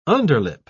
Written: underlip

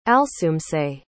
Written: alsoomse

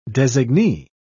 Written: designee

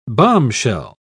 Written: bombshell

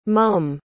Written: mumm

